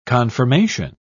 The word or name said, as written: confirmation